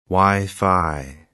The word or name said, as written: Wi-Fi